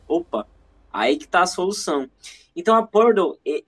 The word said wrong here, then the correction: Purdue: eles a Purdue, né, como o William trouxe aqui a pronúncia e acredito que seja correta.